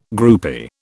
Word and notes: groupie: British English

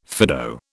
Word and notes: Fido: British English

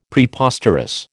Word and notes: Preposterous: American English

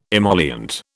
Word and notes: emollient: British English

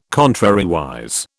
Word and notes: contrariwise: British English

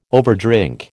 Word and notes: overdrink: American English

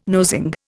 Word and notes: nosing: Indian

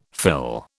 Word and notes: fill: British English

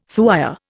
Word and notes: thuya: Indian

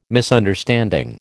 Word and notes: Misunderstanding: American English

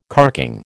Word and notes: carking: American English

